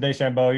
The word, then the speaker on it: DeChambeau: Is that, did I pronounce that correctly? DeChambeau? Is that right? – Yep, Bryson DeChambeau.